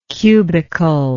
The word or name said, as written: cubical